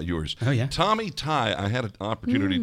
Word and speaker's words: Tighe: And, and his Twitter handle is the GH is silent. – Yeah, because his last name is, is spelled T I G H E.